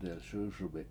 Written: delS!S1bi7

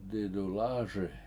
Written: didulAASi

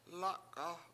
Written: lAk7a7

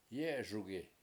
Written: yE7eSugi

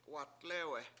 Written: watLEwe7

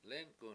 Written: lEnk7un

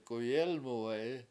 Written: k7uyElmuwe7i

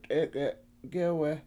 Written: t7EEk7e7 gEwe

